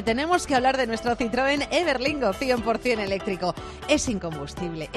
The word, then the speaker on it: Citroën: Es que hay que pronunciar francés como Citroën.